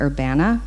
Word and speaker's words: Urbana: I actually was corrected yesterday and told to pronounce it Urbana. I've never lived there. So apparently the locals call it Urbana.